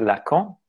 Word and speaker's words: Lacan: Otherwise, I work in the clinic in what is called a Lacanian orientation after the psychoanalyst, named after the psychoanalyst Jacques Lacan, if you say it in the French accent. But I think in the American accent, you say Lacan, right?